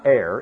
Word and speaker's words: err: It's err. No, it is not err. It is err, like ergo and ergon and ergonomics.